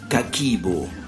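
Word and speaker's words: Kakeibo: Se escribe Kakebo, aunque Hani Motoko y el resto de las japonesas lo pronuncien Kakibo.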